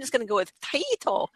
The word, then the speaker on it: Taito: Taito. – Taito.